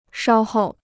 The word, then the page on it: 稍候: shāo hòu